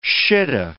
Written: shittah

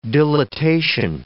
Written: dilatation

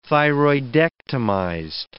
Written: thyroidectomized

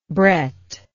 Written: Brett

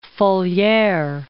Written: Falier

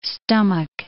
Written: stomach

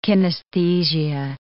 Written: kinesthesia

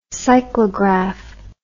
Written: cyclograph

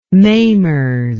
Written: mamers

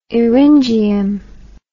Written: eryngium

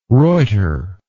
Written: ruyter

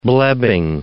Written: blebbing

